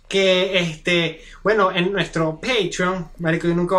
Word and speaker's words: Patreon: No, pero ahorita lo has dicho bien las dos veces. Bueno, Patreon.